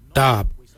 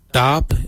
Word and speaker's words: dhaab: Today we will look at, for knife we say darb. Darb is spelled D-H-A-A-B.